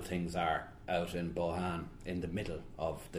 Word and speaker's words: Bohane: First of all, I'm going to read a little bit from City of Bohan, and wonderful English publishers, Jonathan Cape, keep calling it Bohane, the wonderful city of Bohane. So I've been correcting them for the last year about the pronunciation, about getting it right, the Han bit.